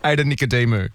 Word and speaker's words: Ada Nicodemu: Ada Nicadu. – Nicadu. – Nicky. – I reckon you're missing a couple of syllables, mate. – What is it? – Ada Nicodemu.